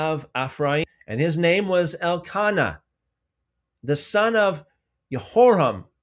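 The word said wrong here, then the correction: Jeroham: I think I said that wrong. Yeroham, the son of Elihu, the son of Tohu, the son of Zuf, an Ephraimite.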